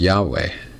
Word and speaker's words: Yahweh: And we think it's probably pronounced Yahweh, Y-H-W-H. So if you ever see that in your Bible, L-O-R-D capitalized, they didn't say Lord.